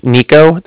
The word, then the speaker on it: Niko: So my name's Nico. That's N-I-K-O. – N-I-K-O.